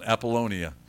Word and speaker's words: Apollonia: It says that when they had passed through Amphipolis and Apollonia, did I say that right? No, I didn't even come close to it. Apollonia? Excellent, Apollonia. My pronunciation is very bad.